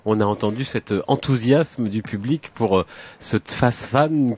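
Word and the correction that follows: Tsfasman: qui est si difficile à prononcer pour nous Français. – Tfasman, Alexandre Tfasman,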